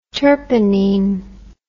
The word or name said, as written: terpinene